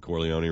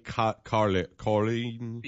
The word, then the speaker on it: Corleone: When the guy's on the stage, you know, giving the check out, and he keeps pronouncing Corleone wrong. – Right. – How did he pronounce Michael and Kate Carleen? – Yeah.